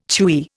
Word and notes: chewy: Indian

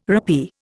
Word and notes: rappee: Indian

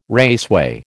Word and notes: raceway: American English

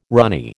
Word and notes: ranee: American English

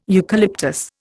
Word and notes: eucalyptus: Indian

